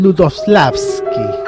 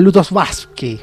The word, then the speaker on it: Lutosławski: Hablé con un polaco y le pedí que lo pronunciara y era algo así como Lutosławski.